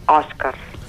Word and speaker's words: Oscars: I la pronúncia l'hem de fer a mà oberta, és a dir, no hem de parlar dels Oscars, Oscars, sinó dels Oscars.